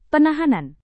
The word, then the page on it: penahanan: Indonesian